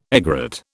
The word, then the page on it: egret: British English